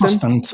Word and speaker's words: Ostend: Usten, is, am I saying that right? Usten? – Ostend, Ostend, Ostend. Yeah. Okay, Ostend.